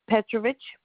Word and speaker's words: Petrovitch: Oh, L-I-Z-E-T-T-E. That's the first name. – And what's the last name, ma'am? – Petrovich. P-E-T-R-O-V-I-T-C-H.